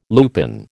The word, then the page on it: lupin: American English